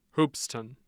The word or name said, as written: Hoopeston